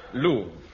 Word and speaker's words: Louvre: Louvre. L-O-U-V-R-E, pronounced...